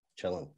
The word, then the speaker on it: Cielen: Cielen.